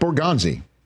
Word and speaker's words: Borgonzi: Mike Borgonzi, he is, I guess it rhymes with Yahtzee, because he's in the catbird seat.